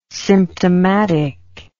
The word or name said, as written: symptomatic